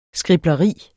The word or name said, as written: skribleri